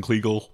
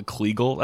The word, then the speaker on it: Kleagle: Like a high ranking like deputy officer of the KKK is called a Kleagle. That's pretty. – How do you spell that? – It's K-E-L-E-A-G-L-E. It's like a Kegel.